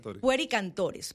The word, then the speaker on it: Pueri Cantores: pueri, padre, ¿se pronuncia así? – Pueri cantores.